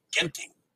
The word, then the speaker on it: Genting: You notice I pronounced Genting correctly.